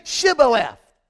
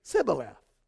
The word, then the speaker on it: Shibboleth: And they would say to them, say Shibboleth. And they would say, Sibboleth. They could not pronounce the H because of their language.